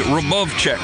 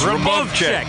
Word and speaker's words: Hrubovcak: If you pronounce it phonetically, it would be J.J. Hrubovcak, H-R-U-B-O-V-C-A-K. – I found an interview with him. It's Hrubovcak. It's Hrubovcak.